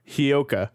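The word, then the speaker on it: Hyouka: Or is that, that's not even how you pronounce it, is it? Hayoko? – Nope. Your cheat sheet's doing wonders. – Hyoka? – Yeah. Hyoka. Hyoka is going to be at number 11, behind Evangelion.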